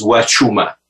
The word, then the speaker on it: Huachuma: By the way, when you were referring to the website, the expression is Huachuma. – Huachuma, okay? So I said it wrong.